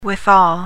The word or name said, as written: withal